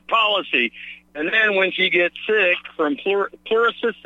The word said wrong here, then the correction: pleurisy: if I'm pronouncing it correctly? – Pleurisy. – It's an inflammation of the pleural membranes, correct? – Yeah, pleurisy, yeah.